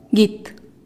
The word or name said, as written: git